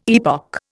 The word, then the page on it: epoch: Indian